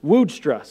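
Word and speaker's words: Woudstra: Commentator Martin Woodstra, I just decided how I was going to pronounce his name. I didn't think about that beforehand. Woodstra. So from here on out, that's how I'm going to say his name.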